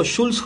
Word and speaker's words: Jools: Un programa que, si pueden buscarlo, Jules Holland, se escribe J-O-O-L-S, Holland como Holanda, sin la última A.